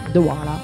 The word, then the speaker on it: Dewaele: Loro dicono Douala, David Douala, Steph Douala.